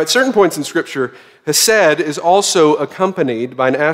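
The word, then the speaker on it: hesed (חסד): The Hebrew word for kindness here, which is found over 250 times in the Old Testament, is pronounced hesed.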